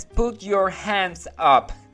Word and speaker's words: put your hands up: Dice, no es Pucho Genso. Más bien es put your hands up. Se pronuncia muy corrido las manos arriba.